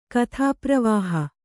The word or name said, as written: ಕಥಾಪ್ರವಾಹ